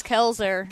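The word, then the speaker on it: Koelzer: Kelzer. – Kelzer? – Yes. – That's not Kolzer? – No, but it's Kelzer. – Kelzer. So a silent O? – Yes.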